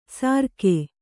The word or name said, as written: ಸಾರ್ಕೆ